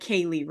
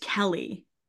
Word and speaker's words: Kelli: It looks like Kaylee, right? – Yeah. – It's pronounced Kelly.